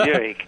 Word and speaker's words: Buick: What is it, a Buck? I couldn't even pronounce Buick.